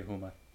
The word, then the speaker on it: Houma: Huma? – Huma? – Huma? – I love that name. Huma. – H-O-U-M-A. Louisiana. – Huma? – Huma. – I'll say Huma.